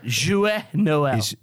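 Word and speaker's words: Joyeux Noël: You got to say it right. Joyeux Noel. – Joyeux. – Joyeux.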